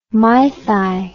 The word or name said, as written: mythi